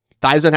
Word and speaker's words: Theismann: Joe Theismann? Am I saying that wrong? – Theismann. Theismann. – Is it Theismann? – Yeah, it's Theismann.